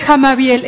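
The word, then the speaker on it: Hamabiel: Es Jamabiel, es H-A-M-A-B de bueno y el Jamabiel.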